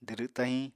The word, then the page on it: dwdw tahiñ: Apatani